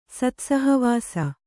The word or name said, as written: ಸತ್ಸಹವಾಸ